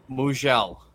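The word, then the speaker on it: Mougenel: Pronounce it again for me. – Mojel. – Mugel.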